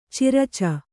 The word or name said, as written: ಚಿರಚ